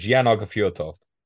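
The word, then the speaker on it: Jan Aage Fjørtoft: The journalist, you told me how to pronounce his name and I can't remember. – I did tell you how to pronounce his name. Go on, do it. – Jan Arge Ford something. – You really are the ideal replacement for Serge. Jan Arge Fjortov.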